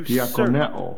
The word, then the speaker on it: diakoneo: Dakineos, dakineo means to Diakoneo. serve. – Okay, thank you, Google.